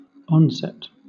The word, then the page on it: onset: British English